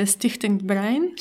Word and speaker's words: Stichting Brein: But it became European when, in a completely different case, in the Netherlands, how can you pronounce this association? – Yeah, this is a Stichting Brein. – Stichting Brein went against the internet service providers Ziggo and XS for All.